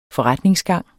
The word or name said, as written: forretningsgang